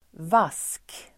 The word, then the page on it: vask: [vas:k]